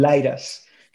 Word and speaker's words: Litus: Y así es como creció Litus, se llama Litus Music, o en inglés eh, lo pronuncian Liras, que es un juego de palabras, Light Us, ¿no?